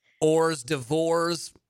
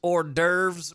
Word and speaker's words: hors d'oeuvres: Man, I want to get that Blue Currrakkko drink and then maybe before dinner have some Ors d'Ors. That will be good. – Some what? – Some Ors d'Ors. – What's that? – I believe it's pronounced hors d'oeuvres, but oh, it's not spelled.